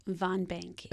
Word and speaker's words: VonBank: VonBank.